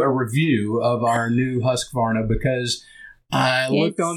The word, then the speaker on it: Husqvarna: You have to say it correctly, though, I think. – Well, what's what was it? – Husqvarna. – Oh, right. So I mean, it is Swedish.